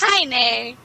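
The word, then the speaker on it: Haine: Well, it's H-A-I-N-E. Nay. That would be how it's pronounced. – Hi, Nay.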